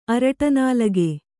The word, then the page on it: ಅರಟನಾಲಗೆ: araṭanālage